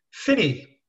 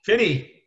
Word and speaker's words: Finney: Have I got the pronunciation of your surname right, John? – Finney. – Finney. – Finney. The original spelling, F-I-N-N-A-E. In Gaelic, it means soldier.